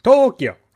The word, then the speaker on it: Tokyo: Was that Tokyo? Is that how they pronounce it? – No, Tokyo. – Tokyo, yes.